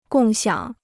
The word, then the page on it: 共享: gòng xiǎng